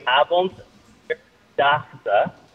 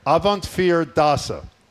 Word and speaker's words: Avondvierdaagse: So it is, I can say slowly, Avondvierdaagse. – Avant, what's the middle? – Avondvier, the number 4, fear. Avondvierdaagse.